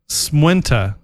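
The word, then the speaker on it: Smaointe: Enya's song, it's pronounced Smuinte. Smuinte? It's an Irish Gaelic. The whole song's in Gaelic.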